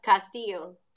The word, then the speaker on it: Castillo: Yes, Castillo.